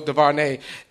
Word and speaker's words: DuVernay: Yeah, Ava DuVernay. – Put the nay on the end. – All right, so he said nay. So DuVernay, she is the bomb diggity, and she on her.